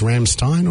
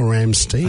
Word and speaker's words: Rammstein: Simon, I mean, but talk me through, do you think it's Rammstein or Rammstein? – I think it's the same as potpourri and potpourri. – I said potpourri. Is that not factually... – I think you could say both pronunciations, yes, but we know who you're talking about.